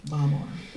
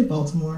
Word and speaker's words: Baltimore: That's how they would say it, but I don't say it like that. I say Baltimore and I say Washington.